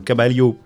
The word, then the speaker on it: Caballo: Et notamment pour une première chanson qui se nomme Caballo Viejo, je l'ai bien dit. – Oui, alors les deux L, c'est prononcé comme un I chez nous.